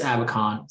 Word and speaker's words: Abacon: Just you can find me on LinkedIn, just Chris Abacon. So my last name is spelled A Bacon.